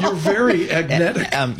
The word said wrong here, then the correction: agentic: Is that how you say it? Agentic? – Agentic AI.